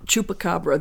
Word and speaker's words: Chupacabra: And you can help me with this pronunciation. – Chupacabra. – Chupacabra. There we go.